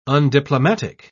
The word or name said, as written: undiplomatic